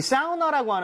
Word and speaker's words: sauna: I mean, 이 사우나라고 하는 거 아마 처음 써보셨을 것 같은데 S-A-U-N-A예요. 근데 이거를 진짜 사우나. 이렇게 이렇게 하는 사람도 있습니다. 근데 일반적으로 전 추천하는 것이 AU 혹은 AW라는 스펠링이 보이잖아요. – 예. – 그러면 아우보다는 이런 느낌. – 사우나. – 사우나. – 사우나. – 느낌. – 그렇죠. 예, 예, 예. – 그래서 sounds like 예를 들어서 법 할 때도 AW죠. – 예. – law 이렇게 가듯이 라우 안 하잖아요.